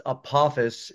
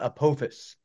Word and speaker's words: Apophis: And we can clear up something right now that it is pronounced Apophis and not Apophis.